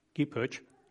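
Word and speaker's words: Kipchoge: Úgy hívják őt, hogy Elihud Kipozsi. Kipsozsi, nem tudom pontosan, hogy kell kiejteni a nevét. Valaki tud segíteni? Kipöcs. Oké, akkor én is tanulok valamit, nem csak tanítok.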